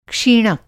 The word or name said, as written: क्षीणक